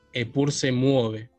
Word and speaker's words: Epur se mueve: Eh, bueno, lo dije bien el nombre de la banda, eh, ¿cómo se pronuncia? Perfecto, lo dijiste.